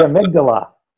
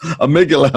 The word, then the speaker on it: amygdala: And in your book, there's a theme that you talk about how to tame your, I hope I say this correctly, amygdala? – Oh, I love that. The amygdala. – Amygdala, okay.